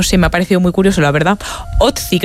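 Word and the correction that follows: Ötzi: creo que se llamaba, un nombre muy, muy noruego. – Utzi, ¿no? – No sé, lo pronuncias tú mejor que yo. – Utzi, vale.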